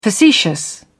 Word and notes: facetious: British English

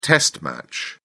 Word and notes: test match: British English

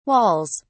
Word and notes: walls: British English

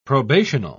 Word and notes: probational: British English

